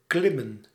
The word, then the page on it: grimper: France; [ɡʁɛ̃.pe]